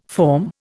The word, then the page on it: form: Indian